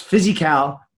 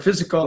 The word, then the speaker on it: Fizzycal: I always thought it was pronounced Physical because of the way it's spelled, but Physical. Yeah. But F-I-Z-Z-Y-C-A-L and...